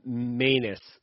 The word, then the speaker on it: Manus: And it's pronounced manus, as in anus.